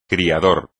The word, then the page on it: criador: espagnol